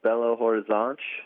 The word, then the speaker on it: Belo Horizonte: It's a city called Belo Horizonte, and you pronounced that correctly.